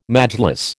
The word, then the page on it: Majlis: American English